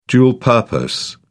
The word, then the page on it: dual purpose: British English